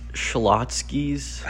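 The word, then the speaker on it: Schlotzsky's: This next one's pronounced Shlotsky's. – I think that's how you pronounce it, It's a bakery and cafe.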